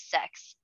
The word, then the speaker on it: Xhex: No, it's spelled like X-H-E-X. So it could be like Sex. – Stop it. – But it's essentially Sex.